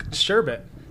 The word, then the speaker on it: sherbet: There's no R. There's no second R. – There's definitely a second R. – No, there's not. It's sherbet.